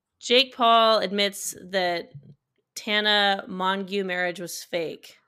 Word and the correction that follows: Mongeau: That's Mojo. – What did I say? – Mongeau? – That's you spell. – I know. – Mojo? It's spelled Mongeau. – She goes by Tana Mojo.